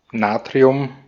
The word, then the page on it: Natrium: /ˈnaːtʀi̯ʊm/; /ˈnaːtʀiʊm/